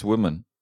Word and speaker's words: Wyman: Ja, und Jane Wyman, ich glaube, die ist primär schon auch bekannt geworden. – Sie heißt Women. – Women? – Ja, wirklich? Also Women, also das Y wird nicht als I ausgesprochen.